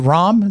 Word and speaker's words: Rahm: I think his name is John. Is it Rahm? It's pronounced R-A-H-M, Rahm.